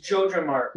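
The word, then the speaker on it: JojaMart: Yaya Mart or the Yoja, the Walmart or whatever. – It's Joja Mart.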